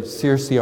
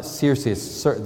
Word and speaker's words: Searcy: I want to thank the elders for allowing Becky and I the opportunity to spend this past week in Searcy, Arkansas. I learned, it's not Searcy, they say Searcy, but it's spelled S-E-A, like Sears store, but they say Searcy, Arkansas.